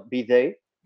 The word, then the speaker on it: bidet: Luego están los que dicen que el bidet fue creado en Francia durante el siglo XVII y su nombre, pronunciado bidet, deriva de la palabra francesa para pony, ¿ok? – Igualito.